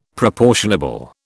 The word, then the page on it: proportionable: British English